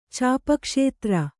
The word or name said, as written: ಚಾಪಕ್ಷೇತ್ರ